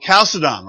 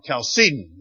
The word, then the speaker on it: Chalcedon: And we use this term to describe the doctrine hammered out at the Council of Chalcedon, or Chalcedon. You know, pronunciation can go either way.